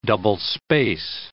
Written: double-space

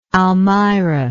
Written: almirah

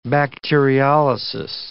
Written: bacteriolysis

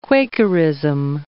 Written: Quakerism

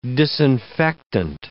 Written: disinfectant

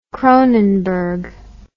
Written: Kronenbourg

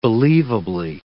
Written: believably

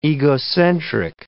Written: egocentric

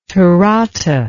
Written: Tarata

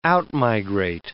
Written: out-migrate

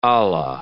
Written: Allah

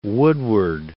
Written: woodward

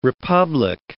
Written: republic

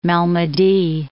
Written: Malmedy

